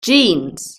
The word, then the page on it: jeans: British English